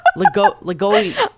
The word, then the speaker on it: LaGoglia: There's no L in there besides the first one. So. – Oh, LaGoglia.